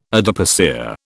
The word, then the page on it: adipocere: British English